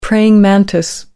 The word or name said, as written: Praying mantis